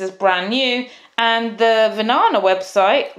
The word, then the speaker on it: Vinana: And Vinana is spelled V I N A N A. And yes, so many people tell me that Vinana rhymes with banana and they just remember it by banana.